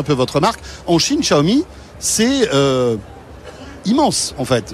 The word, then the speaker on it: Xiaomi: Avec la prononciation de Xiaomi aussi. Tout à fait, Xiaomi.